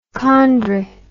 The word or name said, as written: chondri